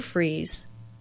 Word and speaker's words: Fries: And this is the Sue Fries Show, Sue Fries, spelled like fries, one word, .com.